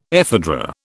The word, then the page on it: ephedra: British English